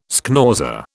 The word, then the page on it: schnauzer: British English